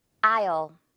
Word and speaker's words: I'll: 네, 지금 이 double L 부분은요 당연히 will이라고 제대로 발음되지 않고 이렇게 발음이 됩니다. I'll, I will 이렇게 열심히 말하지 않고 그냥 I'll 이렇게 줄여버리는 거예요.